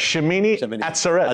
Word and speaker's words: Shemini Atzeret: It's a mysterious day called Shemini. Am I pronouncing it right? Pretty much. – Shemini Atzeret. – Atzeret. Yeah, people won't know about it, but if you look, it says, this is the mystery day.